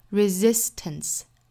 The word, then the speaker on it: resistance: Braveness is the resistance resistance 这个词里面第一个这个 s 呢应该是读 z resist 后面的这个 s 应该是轻的 resistance